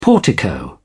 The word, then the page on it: portico: British English